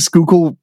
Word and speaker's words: Schuylkill: So I bet he's super super pumped right now that we're talking about Schuylkill and saying Schuylkill properly because it's spelled all weird.